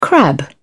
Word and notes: crab: British English

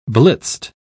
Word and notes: blitzed: British English

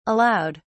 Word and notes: allowed: British English

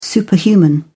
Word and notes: superhuman: British English

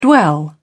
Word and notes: dwell: British English

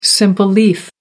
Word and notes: simple leaf: American English

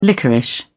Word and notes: liquorice: British English

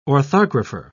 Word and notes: orthographer: British English

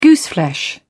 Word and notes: gooseflesh: British English

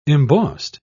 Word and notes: embossed: British English

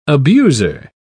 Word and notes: abuser: British English

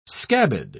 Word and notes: scabbed: British English

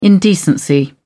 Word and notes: indecency: British English

